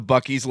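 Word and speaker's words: Buc-ee's: And then it has the Bucky's logo, which is, I guess it's a, what is that? – It's a beaver.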